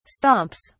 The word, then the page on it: stomps: British English